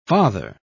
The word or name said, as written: father